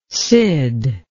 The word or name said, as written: cyd